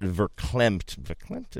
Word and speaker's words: verklempt: verklempt, is that, am I saying that right? – Yeah.